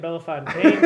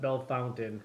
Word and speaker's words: Bellefontaine: No offense to everyone in Bellefontaine, Ohio, but it is not pronounced Bellefountain. It cannot be pronounced Bellefountain. It is not spelled Bellefountain. It is clearly a French word. It is Bellefontaine. It is not Bellefountain.